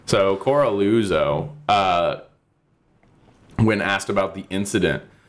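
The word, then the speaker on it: Coralluzzo: So the next day, Dennis Carluzzo, or whatever the fuck his name is. I keep mispronouncing his name wrong, but it's not on purpose. It's just that I don't care. And it's, I think, just it's like Coraluzzo. – Coraluzzo.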